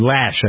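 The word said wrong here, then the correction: Loesch: I believe is how you pronounce her last name. – Her name is pronounced Lesh. – Lesh. Dana Lesh. L-O-E-S-C-H, I believe.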